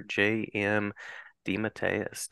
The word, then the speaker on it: DeMatteis: I am delighted on this episode to be talking with creator J.M. DeMatteis.